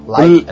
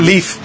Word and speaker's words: Leif: Life? É Leaf. – Erickson. – Leaf. Leaf. Leif Erikson.